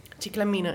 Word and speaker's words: Ciclamina: Maglia Ciclamina, zeg ik dat goed? – Ciclamina, ja. – Ciclamina.